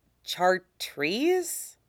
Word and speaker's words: chartreuse: How do you pronounce chartreuse? Chartreuse, Mace says helpfully.